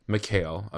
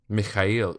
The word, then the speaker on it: McHale: Well, the white way of saying it is McHale, but so that's like how we've always said it. But the proper pronunciation, if you want to know, is Mikhail. – Mikhail.